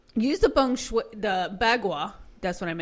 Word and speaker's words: bagua: It's called a bagua. Bagua. – How do you spell it? – B-A-G-U-A.